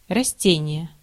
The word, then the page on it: растение: /rɐsˈtʲenʲɪjə/; rastenije